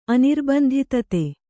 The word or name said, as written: ಅನಿರ್ಬಂಧಿತತೆ